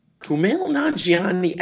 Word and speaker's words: Kumail Nanjiani: Kumail Nanjiani. Kumail Nanjiani.